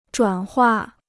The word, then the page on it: 转化: zhuǎn huà